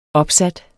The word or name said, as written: opsat